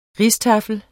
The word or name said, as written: rijsttafel